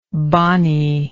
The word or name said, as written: bonniness